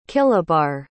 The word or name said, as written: kilobar